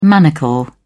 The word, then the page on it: manacle: British English